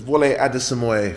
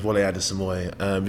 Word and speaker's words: Wole Adesemoye: So it's Wole Adesemoye. Full name's Wole Adesemoye.